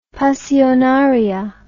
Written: pasionaria

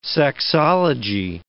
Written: sexology

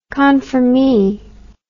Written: confirmee

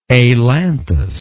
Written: ailanthic

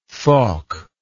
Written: thokk

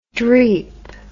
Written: dreep

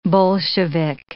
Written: Bolshevik

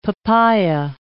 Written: papaya

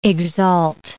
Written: exalt

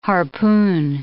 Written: harpoon

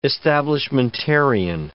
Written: establishmentarian

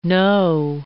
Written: Noh

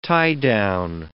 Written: tie-down

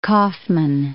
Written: Kaufman

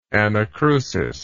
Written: anacrustically

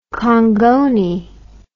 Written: kongoni

